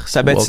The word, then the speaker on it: Sabatini: On a appris euh, cette semaine là, que, que Walter, Walter Sabatini. Walter Sabatini Merci. Ton, ta prononciation est bien meilleure que, que la mienne.